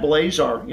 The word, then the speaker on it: Blazar: And then, of course, just Brad Blazar. You know, my last name is spelled B-L-A-Z like zebra, A-R.